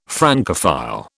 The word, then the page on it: Francophile: British English